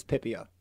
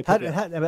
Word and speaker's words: Pipia: Well, actually, the correct pronunciation would be, well, the American is Pipia. – Okay. – That's the way we say Pipia.